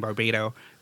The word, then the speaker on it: Barbato: And if you're just listening and you're interested in project management like I am, you can follow me on Twitter at Jonathan Barbato. – Could you spell that? Because yeah, so it's Jonathan, JONATHAN, it's my last name, Barbato, BARBATO.